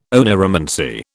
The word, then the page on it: oneiromancy: British English